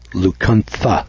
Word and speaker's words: Lacunza: The guy's name is pronounced with TH, although it's spelled L-A-C-U-N-Z-A. The pronunciation that I get here is Lucuntha, T-H-A.